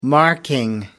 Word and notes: marking: American English

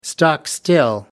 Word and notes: stock still: American English

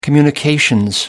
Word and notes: communications: British English

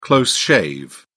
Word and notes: close shave: British English

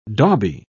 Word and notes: dauby: British English

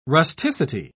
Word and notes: rusticity: British English

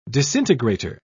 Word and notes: disintegrator: British English

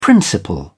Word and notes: principle: British English

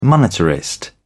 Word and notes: monetarist: British English

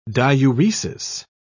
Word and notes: diuresis: British English

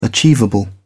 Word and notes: achievable: British English

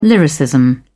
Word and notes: lyricism: British English

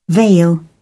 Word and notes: veil: British English

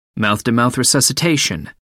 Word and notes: mouth-to-mouth resuscitation: British English

